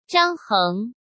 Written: Zhang Heng

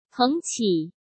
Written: Peng Qi

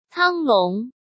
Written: Tang Long

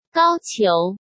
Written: Gao Qiu

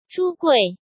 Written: Zhu Gui

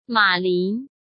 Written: Ma Lin

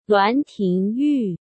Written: Luan Tingyu

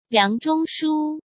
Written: Liang Zhongshu

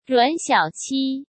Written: Ruan Xiaoqi

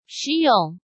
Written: Shi Yong

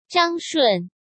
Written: Zhang Shun

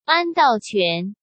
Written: An Daoquan

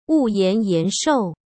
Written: Wuyan Yanshou

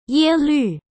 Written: Ye Lu

